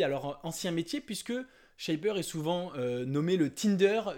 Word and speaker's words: Shapr: Donc, ça se prononce pas Sharp ou Chapre, hein, c'est Shapr.